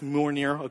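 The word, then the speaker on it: Mjölnir: This is Captain America with the Captain America shield and his little Thor hammer, pronounced Mjolnir.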